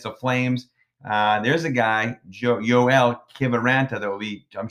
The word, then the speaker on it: Joel: The name, it's spelled J-O-E-L, Joel, but no, it's pronounced Yoel. Yoel, last name, Kivaranta.